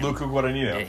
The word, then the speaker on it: Guadagnino: Your boy, we talked about him a lot on the, or his films at least a lot on the Oscar podcast, Guadagnino, or however you say his name. – Luca Guadagnino.